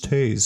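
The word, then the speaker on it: Teays: I'm having a Tay's River. I'm pretty sure I'm saying that right. It's spelled T-E-A-Y, but I'm pretty sure everybody in Lafayette, when I say it, I say Tay's, and they're like, no, it's Tay's.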